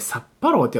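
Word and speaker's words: 殺破狼: えっと、現代は、えっと、殺す破る狼って書いて、サッパローって読むかなの、サッパローっていう映画の 2 なんですよ。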